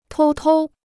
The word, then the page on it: 偷偷: tōu tōu